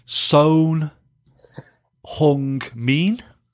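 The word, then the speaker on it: Son Heung-min: So he says, the O is long like in open, the EU is a short U like in put. It is not a U sound as you often hear from commentators. The I is a long E sound like in seen. So I make that sewn hung mean.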